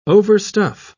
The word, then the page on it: overstuff: British English